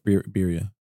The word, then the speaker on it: birria: First stop is going to be some birria tacos. Which birria. – Birria? – Birria. Birria. – Birria. – No, you said it right.